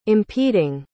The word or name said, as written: impeding